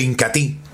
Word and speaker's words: Incatea: Ella sabe, mis amigos, algo importante como lo es precisamente el incatí. Se escribe incatea, pero se pronuncia incatí.